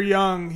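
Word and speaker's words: you (六): So Dr. Young, he said that the Chinese number six is pronounced you, I guess. The number six in Chinese is pronounced you, he said.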